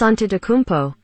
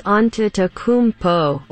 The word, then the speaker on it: Antetokounmpo: Giannis Antetokounmpo. Giannis Antetokounmpo.